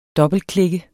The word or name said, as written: dobbeltklik